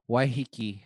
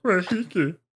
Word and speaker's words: Waiheke: Kimmy says that Jonas and I are getting married in Waiheke. – That's not how you say it. – You say it like Waiheke.